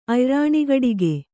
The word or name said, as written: ಅಯ್ರಾಣಿಗಡಿಗೆ